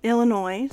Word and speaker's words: Illinois: When Congress admitted Illinois, oh my god, I always forget how to say it. Illinois. No S.